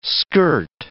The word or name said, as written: skirt